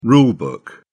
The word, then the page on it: rule book: British English